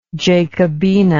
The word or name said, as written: Jacobina